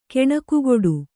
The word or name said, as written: ಕಾಣಿಕೆಗೊಡು